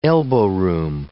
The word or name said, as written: elbow room